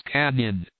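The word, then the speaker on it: Cañon: And up in's Canon, please pronounce Canyon, after the manner of the natives,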